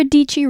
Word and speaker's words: Deechy: I'm Sarah Deechy, rhymes with peachy.